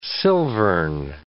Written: silvern

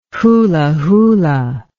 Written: hulahula

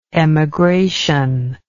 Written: emigrational

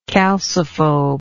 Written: calciphobe

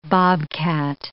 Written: bobcat

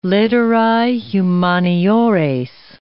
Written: litterae humaniores

